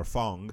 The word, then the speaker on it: Fang: Fang, if you want to call him. Or Fong, because I heard him, the announcer, say his name whenever he wins a fight in the trailer.